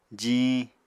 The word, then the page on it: jiñ: apatani